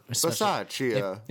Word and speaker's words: Bisaccia: Bisaccia. That's how you say it.